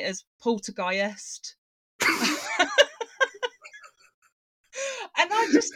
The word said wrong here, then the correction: Poltergeist: So someone told me to say it like, remember, it's like heist. So Poltergeist.